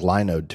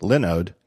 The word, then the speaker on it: Linode: Looks like Linode to me, but it's pronounced Linode because they specialize in Linux.